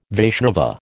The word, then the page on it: Vaishnava: American English